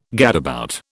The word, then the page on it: gadabout: British English